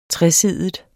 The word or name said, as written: TRESIDIG